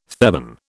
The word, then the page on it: Theban: British English